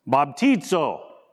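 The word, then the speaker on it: Baptizo (βαπτίζω): Here's how you say it. Baptizo.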